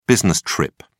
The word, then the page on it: business trip: British English